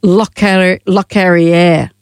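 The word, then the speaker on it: Locorriere: His not mate- Lockery. He has passed away. Let's say his name correctly, she said, looking for the piece of paper. Lockerriere.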